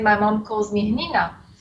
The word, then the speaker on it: Hnina: It's Hnina Gourmet. Hnina is H-N-I-N-A. H is silent for most nationalities. Gourmet, G-O-U-R-M-E-T.